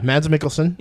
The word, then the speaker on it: Mads Mikkelsen: Mads Mikkelsen, I believe is how you say it. – Yeah, Mads Mikkelsen.